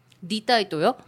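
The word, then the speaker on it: Lý Thái Tổ: 한자로 그렇게 베트남어로 발음하면 리따이또인데 네. – 그걸 우리나라 말로 발음을 하면 이태조입니다.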